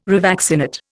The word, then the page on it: revaccinate: Indian